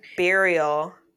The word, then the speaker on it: burial: So they believe that it was a Native American bureau. Is that how you say it? – Like a dresser thing? – Like, no, like a graveyard. – Oh, burial. – I cannot say that word.